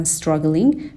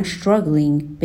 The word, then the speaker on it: struggling: این رو میدونیم توی تلفظ آمریکایی STR در ابتدای کلمه یه وقتایی صدای ش میده, یعنی به جای اینکه بگن struggling میگن struggling, به این شکل تلفظ میکنن.